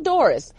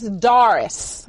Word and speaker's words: Doris: It's not Doris. It's Doris. I want to make sure. I have a little bit of a Southern accent. But yeah. I do, it is pronounced Doris, isn't that correct?